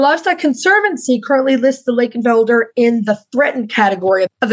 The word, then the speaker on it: Lakenfelder: You'll see the name sometimes spelled with an F. Again, that's the German spelling. Instead of that V, still mostly pronounced as an F, Lakenfelder.